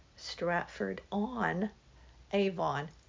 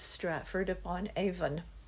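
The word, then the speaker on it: Stratford-upon-Avon: Now, in school, in college, I have always heard it very clearly spoken. Stratford on Avon. Clearly to Avon. But the proper pronunciation isn't that. It's Stratford upon Avon.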